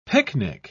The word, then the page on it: pyknic: British English